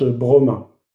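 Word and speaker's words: broma (βρῶμα): Le mot nourriture utilisé ici dans le grec, euh, c'est un mot qui, qui se prononce broma.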